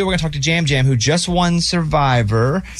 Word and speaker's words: Yam Yam: Yam Yam? – Jam Jam. – Oh, it's spelled Yam Yam, though. – Yeah, but he's from Puerto Rico, so I guess they say it Jam Jam.